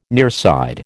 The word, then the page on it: nearside: American English